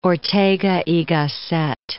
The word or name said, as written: Ortega y Gasset